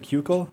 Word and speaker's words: Keuchel: So Dallas Keuchel was, I believe that's how you pronounce his name. Is it right? Keuchel? – Keuchel. – Keuchel? – Dallas Keuchel.